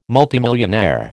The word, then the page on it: multimillionaire: American English